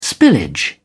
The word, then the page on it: spillage: British English